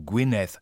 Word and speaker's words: Gwynedd: Tatsächlich ist dieses Doppel-D zum Beispiel, also so schreibt sich diese Grafschaft mit 2 D am Ende, spricht man als auf Walisisch als ein weiches TH aus, also Gwynedd.